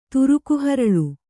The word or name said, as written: ತುರುಕುಹರಳು